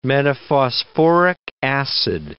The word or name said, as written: metaphosphoric acid